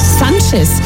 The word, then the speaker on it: Sanchez: Sanchez.